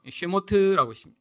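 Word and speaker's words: 쉐모트 (שמות): 자, 두 번째 그럼 출애굽기는 어떻게 히브리어로 읽나요? 쉐모트라고 읽습니다. 쉐모트는 이름이라고 시작해요.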